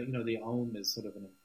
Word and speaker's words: Aum: It's actually not pronounced Om, it's pronounced Aum. Aum, that's what it is.